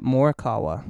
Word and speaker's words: Morikawa: So It's Colin Morikawa. – Colin Morikawa. Morikawa? – Yep, Morikawa.